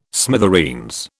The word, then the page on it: smithereens: British English